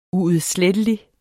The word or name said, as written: UUDSLETTELIGE